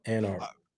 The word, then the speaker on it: Anarc: Yep, Anarc.